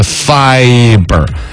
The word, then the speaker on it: fiber: 有一个英语发音我要说一下，今天节目里头说那个 separate 的那个重音是在发上头，还有之前那个典型的广告那个 fiber to room 那个 fiber 发音应该是 fiber 王黑石，天天听你读，有时候呢就开始怀疑自己了。